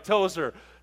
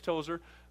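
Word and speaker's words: Tozour: Okay, well, I'll spell Rich Tozer for you. Ready? R-I-C-H. Okay, got that. Figure you do well with that. All right, Tozer. T, like Tom, O-Z-O-U-R. T-O-Z-O-U-R.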